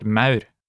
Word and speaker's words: Maur: This is like the AU, as in sauerkraut, AU. An example of this is in the word Maur, which refers to ants.